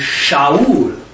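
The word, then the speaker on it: Shaul (שאול): But what we're going to look at is David, and actually how you pronounce his name is Dawid, like W, or D-A-W-E-A-T-H, Dawid. And Saul is Shaul, like S-H-A-U-A-L, or something like that. I don't know how you, phonetically how you spell it. But it'd be Dawid and Shaul.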